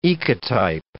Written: ecotype

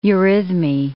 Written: eurythmy

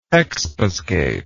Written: expiscate

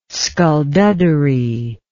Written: sculduddery

